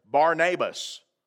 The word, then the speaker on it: Barnabas: So when you think Barnabas as a sort of an English name, that's how we pronounce it, but think of it for just a moment, if you would, as Barnabas, with an emphasis on that first syllable, bar.